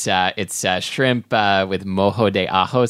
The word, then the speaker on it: ajo: I think you mean Mojo. – Okay, yes. It's Ajo. – Mojo to Ajo.